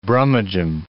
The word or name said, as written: brummagem